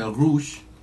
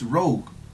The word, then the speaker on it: Rogue: Entonces, si una persona lee esta palabra y cree que es en francés y la pronuncia Rouge, ¿cómo? ¿Está bien o está mal? – Mulan. – Está bien que yo le diga, no, es Rogue. O sea, yo sé que es Rogue, porque en inglés la wea.